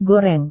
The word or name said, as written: goreng